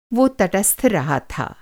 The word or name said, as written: तटस्थ